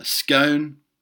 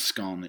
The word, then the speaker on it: scone: The correct pronunciation of scone, it's not scone, it's scone, for example, and what stage of the tea-making process one should add milk.